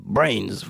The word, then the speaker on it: BRNS: C'était Pius Platitudes euh, de BRNS qui se propose, qui se prononce, pardon, Brains.